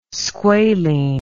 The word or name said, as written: squalene